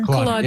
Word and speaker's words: Kolodner: That is the great Baltimore banjo player, Brad, I don't know how to pronounce his last name, Kolodner? Kolodner? – Kolodner. – Yeah. Kolodner.